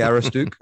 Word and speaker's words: Aroostook: I say Aroostook.